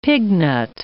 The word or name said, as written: pignut